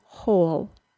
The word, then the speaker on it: whole: When the WH is followed by either the long O sound or the OO sound, then the word is usually pronounced with an H sound and only an H sound. This includes the words who, whom, whose, and whole. You should notice that the words whole, W H O L E, and hole H O L E, are pronounced exactly the same.